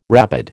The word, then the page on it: rabid: American English